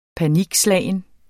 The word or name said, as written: panikslagen